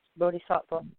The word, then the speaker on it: bodhisattva: I don't have an overheading for them, but they are, you know, you may call them bodhisattva. – Yes, bodhisattva. Okay. – Yeah. – Ladies and gentlemen, that's spelled B-O-D-I space S-A-T-T-V-A.